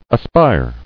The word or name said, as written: Aspire